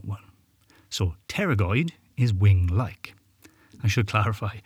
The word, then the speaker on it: pterygoid: These are the pterygoid muscles, spelt with a silent P, as in the flying dinosaur-like creature, the pterodactyl.